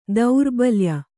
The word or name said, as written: ದೌರ್ಬಲ್ಯ